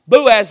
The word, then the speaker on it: Boaz: Boaz is how you pronounce it in the New Testament.